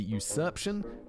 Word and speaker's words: usurpation: As for the charges against Alfredo, he had been accused of clandestine burial, usurpation. Usurpation? You could be usurped. So the pronunciation would be usurpation.